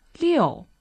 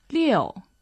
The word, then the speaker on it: 六: Well, six is liu, fourth tone, liu, and seven is qi, first tone.